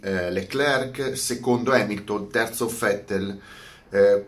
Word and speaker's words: Leclerc: o Leclerc, eh, Leclerc, come Chantecler. Cioè, ma io devo pronunciare, ma cosa me...